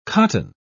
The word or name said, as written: cotton